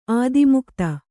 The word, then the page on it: ಆತ್ಮಕಥೆ: Kannada